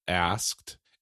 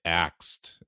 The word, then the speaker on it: asked: I'm just saying there's a particular type of person that doesn't know how to pronounce asked and will say axed instead, as if the K went before the S.